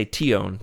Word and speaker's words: Tion: I say Tion, two syllables. – Yeah, I bet you put some exotic twist on it, don't you? – She might say Tione, who knows?